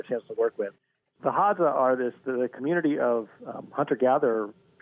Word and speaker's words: Hadza: I don't even know how to say it, Hadza? Is that how you say them? – Hadza? That's right, the Hadza tribe.